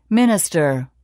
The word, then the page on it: minister: [mínəstər]